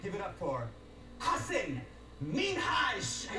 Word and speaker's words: Minhaj: Minhaj. – Minhaj. – So the emphasis on the H.